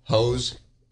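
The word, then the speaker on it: José: The J is pronounced as an H in Spanish. – It's like Jose, J-O-S-E. – Yeah, how do you spell Jose, bro? Hose? – No, J-O-S-E.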